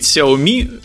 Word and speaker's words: Xiaomi: Нам тут пишут в чатике, что правильно произносить Xiaomi.